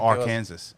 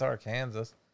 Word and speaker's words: Arkansas: If you think about how Arkansas is spelled, you would never really think. – It's Arkansas. – It's Arkansas. But you just know it as Arkansas. Like, I ain't never heard nobody say Arkansas. But that's a law that you can't say it wrong? – Yeah, it's a state code.